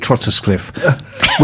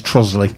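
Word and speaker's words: Trottiscliffe: Yeah, because she calls it Trotterscliff, which is not completely mad, because yeah. that is actually how it's spelt. – Yeah, she's sounding out phonetically. – Yeah, Trotterscliff, but it's actually pronounced locally as Trossley.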